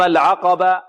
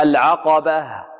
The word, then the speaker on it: العقبة: البعض ربما يقلبها ألفا، يقول فلق تحم العقبة، لا، خطأ، العقبة، العقبة، بارك الله فيكم. هكذا نوضح الهاء، فلق تحم العقبة، عقبة. البعض يقلبها ألفا، والبعض ربما يجافي بين الوترين الصوتيين وهكذا، يعني لا ينطقها واضحة. المهم، هذه التاء نقف عليها بالهاء.